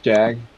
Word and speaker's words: Jagg: So, yeah, like I said, my name is Jag, J-A-G-G, that's how you spell it.